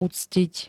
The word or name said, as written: uctit